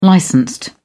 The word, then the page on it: licensed: British English